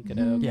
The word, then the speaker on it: Gadot: Is that how you pronounce her name? Gadot. – Yeah, Gadot.